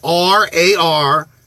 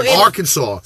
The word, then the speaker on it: Arkansas: And it says RARKAN-SAW. That's how they want it said. So Arkansas.